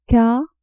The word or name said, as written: k